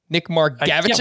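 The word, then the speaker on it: Nick Margevicius: That's how we're pronouncing it, right? Nick Margavichus? – Well, I wasn't expecting you to actually say the name tonight. Yes, that is the Mariners pitcher who took James Paxton's spot and used to pitch for the Padres.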